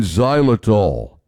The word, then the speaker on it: xylitol: Xylitol. X-Y-L-I-T-O-L. Pronounced xylitol.